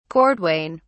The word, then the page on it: cordwain: British English